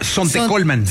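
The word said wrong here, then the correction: Zontecomatlán: Sontecomatlán. – Ay, mira, hasta lo dije mal.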